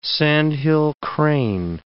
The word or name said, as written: sandhill crane